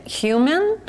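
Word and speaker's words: Heumann: And her name is Judith Human, which is not spelled H-U-M-A-N, but it's spelled so that you pronounce it that way.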